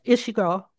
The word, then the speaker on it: Ishiguro: So you relay, I don't know if I'm saying his name right, Hiroshi Ishiguro? Ishiguro? – Yes, Ishiguro, yes.